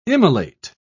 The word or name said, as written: immolate